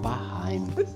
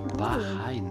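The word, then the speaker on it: Bahrain: Das heißt Bahein. Ein Staat, nennt ihr Dubai. Bahein wird es auch ausgesprochen.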